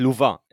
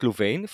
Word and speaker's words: Louvain: First, while talking with French speakers, they insisted on pronouncing it Louvain and not Louvain for some obscure reason.